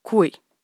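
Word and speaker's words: Kuei: Natacha Canapé, je vais juste prononcer un mot, s'il te plaît, tu me dis si c'est ça ou pas. Coué. Bonjour. – Oui, est-ce que c'est bien ça ? – Oui, c'est ça.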